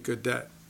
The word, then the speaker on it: Gaudet: And you know, in many ways, it's easier to recognize when you pronounce it Gaudet. You know, because of course that's the way it's spelt, the G-A-U-D-E-T.